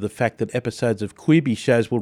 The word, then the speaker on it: Quibi: It's available for download in the Apple and the Google Play Store at the moment, and it's pronounced Quibi. Quibi, there you go.